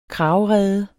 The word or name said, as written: kragerede